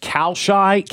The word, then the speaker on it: Kalshi: But as of today, according to playoff percentages, according to Kalashi, or have you ever been on their website? – Kalishi? – I think it's pronounced Kalshi, K-A-L-S-H-I.